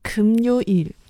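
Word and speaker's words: 금요일: 那韩文的发音呢是“ 금 요 일”。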